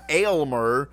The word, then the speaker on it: Aylmer: Elmer, but it's spelled A-L-Y-M-E-R. Aylmer.